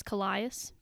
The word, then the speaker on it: Calais: It's spelled C-A-L-A-I-S, and in my brain I read it as Calais, but apparently it's Calais, whatever.